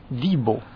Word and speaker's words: Devo: Pues se pronuncia, según dice aquí, hemos buscado. Esto se escribe Devo, ¿no? – Sí. – Y se pronuncia Divo.